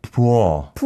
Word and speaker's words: 부어: And then we won't go into the definition, but 얼굴 I think wasn't too bad, but the 부어. – 부어. – 부어. That's tough for, I think, foreigners. – Yeah. – Because it's like these vowel sounds, but two separate syllables.